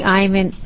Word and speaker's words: Eymann: My name is Katie Eyman, and I'm chair of the Board of Maritime Pilots. My last name is spelled E-Y-M-A-N-N.